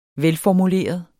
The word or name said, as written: velformuleret